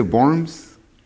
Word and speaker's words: Worms: De Worms. Se escribe en W, dicen que se menciona como V, Worms.